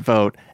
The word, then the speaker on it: Voat: It's called V-O-A-T. It's pronounced Vote, and it's because they felt that...